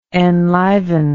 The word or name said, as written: enlivenment